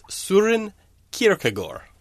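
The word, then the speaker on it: Søren Kierkegaard: And whenever there is, they tell me it's Soren Kierkegaard.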